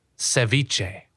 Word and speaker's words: Ceviche: Ceviche.